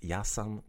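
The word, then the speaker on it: ja sam: And online you can see that we, in the translation, that the ya is spelled J-A. So the J sound is pronounced like a Y sound in English.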